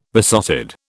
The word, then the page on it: besotted: British English